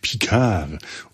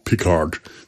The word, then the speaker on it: Picard: Nicht wie jetzt französische MuttersprachlerInnen das aussprechen würden, aber zumindest Jean-Luc Picard und nicht Jean-Luc Picard. Das ist definitiv falsch.